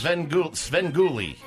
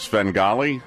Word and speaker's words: Svengoolie: Pronounce it that way on purpose? – Svengoolie. – Not Svengali? – No, Svengoolie. He's a ghoul.